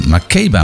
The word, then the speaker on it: Makeba: Miriam Makeba. Makeba moet ik zeggen trouwens. En misschien spreek je het nog wel weer anders uit.